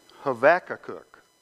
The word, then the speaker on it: Habakkuk: And so I want you to go to the book of Habakkuk or Habakkuk, or the Hebrews pronounce this. Let me see if I can do this. Habakkuk.